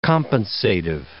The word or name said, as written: compensative